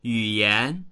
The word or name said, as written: yu3yan2